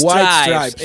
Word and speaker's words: White Stripes: reconocido por ser guitarrista y vocalista de la banda White Strippers. Strips, ¿cómo se dice? Stripes. White Stripes.